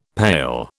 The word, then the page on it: pail: British English